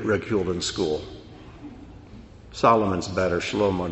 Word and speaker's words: Shlomo: Shlomo, which is how you pronounce the name Solomon in Hebrew.